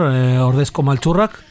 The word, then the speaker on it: Ordezko Maltzurrak: Ordesco Malchurrak, lo he dicho bien.